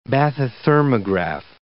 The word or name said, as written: bathythermograph